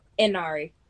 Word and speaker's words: Inari: Inari.